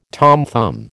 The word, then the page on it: Tom Thumb: American English